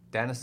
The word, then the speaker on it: Denis: We established before the show it's not Dinus because, as Craig said, there's no I, even though there is an I.